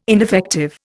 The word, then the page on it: ineffective: Indian